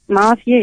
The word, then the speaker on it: Nafiye: Merhaba, ben Nafiye. – Nafiye. Nereden arıyorsun? – Evet. Konya'dan. – Nafiye midir, Nafiye midir doğru telaffuzu? – Nafiye ya.